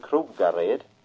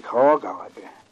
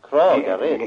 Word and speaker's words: Kragared: Och sen ligger något ovanför som heter Krogared. I Torup. Står det Krogared, står det. – Nej, det är Kragared. – Kragared? – Det är Jönsleshoppen.